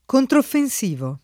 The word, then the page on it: controffensivo: lingua italiana